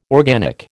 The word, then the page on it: organic: American English